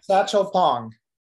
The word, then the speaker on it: Satchel Pong: Satchel Pong. Satchel as in Satchel Paige, the baseball player, and Pong as in ping pong.